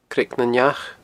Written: Creag nan Each

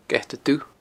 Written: geatadubh

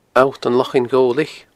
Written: Allt an Lochain Ghobhlaich

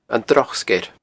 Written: Droch Sgeir

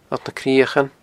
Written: Allt nan Criochan

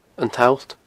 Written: An t-Allt

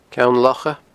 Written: Ceann Locha

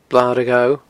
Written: Blar a' Ghobha

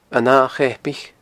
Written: An Ath Cheapaich

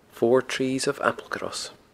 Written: Four Trees of Applecross

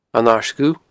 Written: An Aird Dhubh

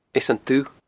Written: Eas an Dubh

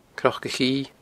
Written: cnocachidhe

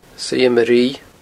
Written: suidhe maruibhe

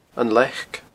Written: leac

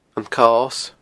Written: Cos

